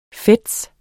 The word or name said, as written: fez